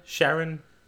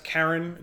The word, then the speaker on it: Charon: Like the Greek, old Greek, I believe it's pronounced Charon. It's the same name as the name of the moon of Pluto.